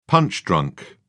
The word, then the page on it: punch-drunk: British English